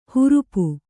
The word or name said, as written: ಹರಪೆ